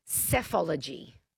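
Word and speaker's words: psephology: Oh, it's I can't even say it. Basology. – Say it again. Go on. – PSE. – Yeah, okay, good. Good. That's the one. It's and it's pronounced cephology.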